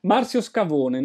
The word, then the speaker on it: Marcio Scavone: How do you pronounce in Brazil? – Marcio Scavone, no, normal, like in Italy, yeah.